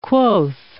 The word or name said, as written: quoth